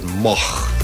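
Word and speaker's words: Moog: uitspreken, zegt de grootmeester zelf. – And how would you pronounce it? Moch.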